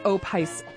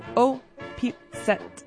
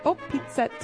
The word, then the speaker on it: Opice: It looks like Opice Polka, but they pronounce it O-P-Set. O-P-Set Polka.